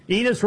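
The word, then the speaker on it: Enes: Enos rhymes with penis.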